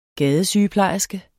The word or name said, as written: gadesygeplejerske